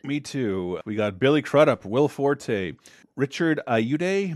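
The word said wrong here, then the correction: Ayoade: Am I saying that right? I gotta know that. I've seen him on so many stupid clip shows. How do you say it then? Ayude? – Ayode? – Ayode.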